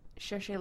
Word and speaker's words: Cherche: But yeah, my current favorite pairing starts out with Stahl is the guy's name, and the girl's name, it's spelled C-H-E-R-C-H-E, which in French is pronounced Cherche.